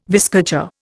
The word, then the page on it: viscacha: Indian